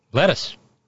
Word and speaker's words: Lettice: Lettice. Lettuce. Lettuce Nollies. Probably pronounced Lettice, but it looks like lettuce on the page.